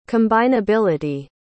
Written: combinability

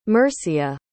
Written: mercia

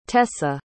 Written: tessa

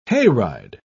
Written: hayride

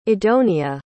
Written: idonia

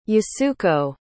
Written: Yasuko

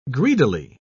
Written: greedily

